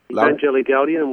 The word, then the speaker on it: Evangelii Gaudium: And those are, and you're going to have to help me in pronouncing this Latin here, Evangelia Gaudium and Laud- Evangelii Gaudium, which is an apostolic exhortation.